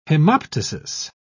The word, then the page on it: hemoptysis: British English